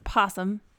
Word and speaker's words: opossum: It is possum, but it's spelled with an O. Possum. It's possum.